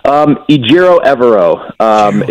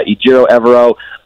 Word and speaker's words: Ejiro Evero: Ejiro Evero? – Ejiro Evero is sort of how we've come to know that his name is pronounced.